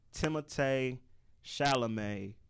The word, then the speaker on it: Timothée Chalamet: So how it's supposed to be pronounced Timothée Chalamet.